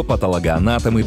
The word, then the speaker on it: патологоанатом: Двойные ударения для русского языка не очень характерны, но это слово – тот самый случай. Есть только один правильный способ его произносить – патологоанатом. Именно его рекомендует орфоэпический словарь русского языка как государственного. Обращаем внимание на ударное «о» после «т». Его часто заменяют на «а», но это неправильно.